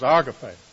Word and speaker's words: agape: Love in the New Testament that I'm speaking about is the Greek word agape, or we pronounce it agape. If I'm looking at the pronunciation correct, is the correct pronunciation is agape, and which according to Zodiates means love, affectionate regard, goodwill, or benevolence.